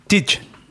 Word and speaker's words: Tite: Wird der Tite ausgesprochen oder Tite? – Und wenn du in Brasilien bist, Tite.